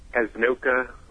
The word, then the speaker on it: Casanoca: Is it Casanocha? – Casanoca. – Casanoca. All right. – Well, we were close. – Kind of like mocha.